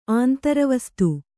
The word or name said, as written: ಆಂತರವಸ್ತು